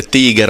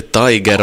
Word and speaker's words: Tiger: Och det här nya projektet heter Tiger, du sa med att man ska uttala det Tiger och inte Tiger, och det är indie-musik.